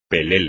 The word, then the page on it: pelele: espagnol